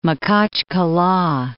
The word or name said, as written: Makhachkala